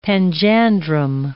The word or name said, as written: panjandrum